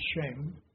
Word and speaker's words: Shem: And if you've ever read very many theologians, they call this lineage of Shem, and in Hebrew is kind of pronounced Shem.